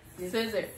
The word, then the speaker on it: SZA: SZA. Just think of SZA, like okay. SZA.